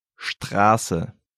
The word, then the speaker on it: Straße: Straße.